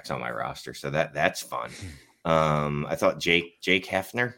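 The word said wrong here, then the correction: Haener: is that how you say his name? Jake Haner? – Him, yeah. Jake Haner? Yeah.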